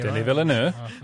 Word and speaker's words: Villeneuve: But my interest in Denis Villeneuve... – Villeneuve. – Did you add an extra syllable? – Oh, my gosh.